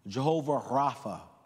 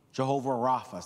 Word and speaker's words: Jehovah Rapha: Now, there's some debate about pronunciation on these. I ran through with Judy, and I take her word over mine. Jehovah Rapha. Jehovah Rapha. Say Rapha. Put it together, Jehovah Rapha.